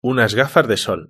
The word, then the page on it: unas gafas de sol: European Spanish